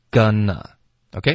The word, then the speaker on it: gonna: ，gonna，OK，、 okay? 就是我这个 going to 的弱读发 gonna，OK，、 okay? 那你发 gonna 的时候，注意不要导致任何鼻音。